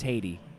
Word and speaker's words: Hayde: No, it's like H-A-Y-D-E, not Haiti. – Oh, shit. So it's not even Heidi. – Yeah. It's Haiti.